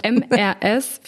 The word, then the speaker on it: Mrs.: Also MRS wird Mrs. ausgesprochen und MS wird Miss ausgesprochen.